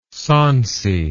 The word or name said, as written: sonsy